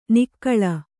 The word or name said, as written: ನಾಗುಳ